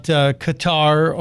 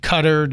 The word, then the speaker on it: Qatar: But Rex Tillerson was also holding a news conference just a few minutes ago, and he was talking about Qatar or Qatar, depending on your pronunciation point of view.